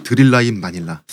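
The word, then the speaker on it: Thrilla in Manila: 이것이 드릴라인 마닐라, 스릴러인 마닐라를 흑인식으로 빨리 발음해서 드릴라인 마닐라.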